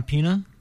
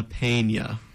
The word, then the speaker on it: Peña: Juliana Pena? No? – That is very wrong. – What is it? – Juliana Peña. – Peña, okay. – So you got to roll that in, man.